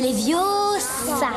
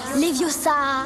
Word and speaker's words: Leviosa: Et puis d'ailleurs, tu ne sais même pas le prononcer. C'est Leviosa. Et pas Leviosa.